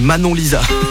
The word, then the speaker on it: Manon Lisa: Le lundi avec plus 30 minutes de Hit Nonstop qui redémarre, Kygo, Paul McCartney et Mona Lisa sur NRJ. Mona Lisa, non, c'est Manon Lisa. Faut bien le prononcer parce que sinon, quand même pas la même personne.